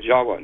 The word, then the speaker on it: Jawa: Actually, Indonesians will pronounce it Jawa, not Java like we do.